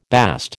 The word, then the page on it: bast: American English